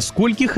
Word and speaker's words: скольких: С этим словом все довольно просто. Не меняем ударение ни при каких обстоятельствах. Сколько, скольким, до скольких, ко скольким, сколькими и так далее.